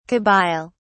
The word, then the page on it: Kabyle: British English